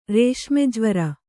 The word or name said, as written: ರೇಷ್ಮೆಜ್ವರ